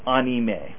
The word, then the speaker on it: anime: I've been taking some Japanese lessons, so if you want to pronounce it authentically, be more like anime.